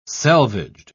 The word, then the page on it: selvaged: British English